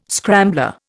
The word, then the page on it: scrambler: Indian